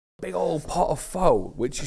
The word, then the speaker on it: pho: And I'm going to pronounce it the English way, because if I pronounce it the natural way, I sound like a git. Pho. – Oof, pho. – Or as they say locally, pho.